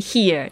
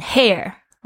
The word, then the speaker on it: hear: Hear 注意一下，听是 hear，因为有些我的学生呢会念成 hair，不是 hair， hair 是头发， hear 才是听到。